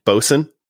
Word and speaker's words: boatswain: I am drinking a chocolate stout from Trader Joe's off-brand, Bosun. Which of course is spelled boatswain because boatswain. Yep. – That boatswain is how it's spelled. But bosun is for some reason how it's pronounced.